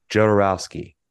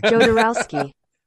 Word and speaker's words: Jodorowsky: If you Google it, how to pronounce Jodorowsky, it is definitely the thing is saying Jodorowsky. – With a J? – Yeah, with a J.